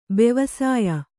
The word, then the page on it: ಬೆವಸಾಯ: bevasāya